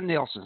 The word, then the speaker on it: Nilsson: It's called the Nielsen study, but it's spelled N-I-L-S-S-O-N.